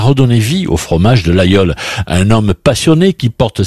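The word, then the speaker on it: Laguiole: Alors, au cœur de ce plateau, un village est connu dans le monde entier, l'Aïole. Ça s'écrit l'Aguiole, mais on prononce l'Aïole.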